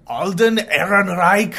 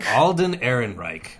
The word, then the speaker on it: Alden Ehrenreich: Alden Ehrenreich. – Alden Ehrenreich, which, apologies, Alden, I just...